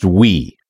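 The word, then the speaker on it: Ooui: I did look up the Wii web framework from Frank Krueger, and it is pronounced Wii.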